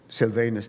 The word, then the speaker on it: Silvanus: Paul, Silvanus, and Timothy. Paul, Silvanus? Silvanus, thank you.